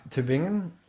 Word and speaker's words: Tübingen: There's an umlaut there. Hit us up. Tübingen.